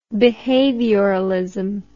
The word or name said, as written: behavioralist